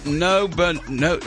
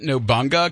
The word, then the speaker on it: Nobunaga: If today's your birthday, you share it with some people who are a pretty big deal, starting with Oda Nobunaga. I always pronounce it Nobunga, because I always thought it was Nobunga's Ambition, the old 8-bit video game.